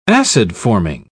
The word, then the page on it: acid-forming: British English